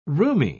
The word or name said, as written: roomy